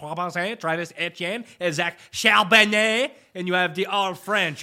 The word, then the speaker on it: Charbonnet: Oh, Zach Charbonnet.